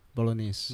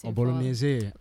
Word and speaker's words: Bolognese: Bolognese. – Yeah, Bolognese.